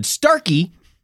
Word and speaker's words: Starkey: And Richard Starkley, better known in Japan. I mispronounced that already. Already I cannot read, listeners. Let's take that back. And Richard Starkey, better known in Japan as Ringu.